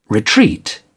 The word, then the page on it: retreat: British English